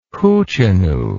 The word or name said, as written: hoochinoo